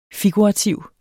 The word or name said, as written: figurativ